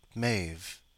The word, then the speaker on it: Maeve: Okay, it's pronounced Maeve, she says. I always get that wrong.